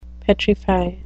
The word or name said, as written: petrify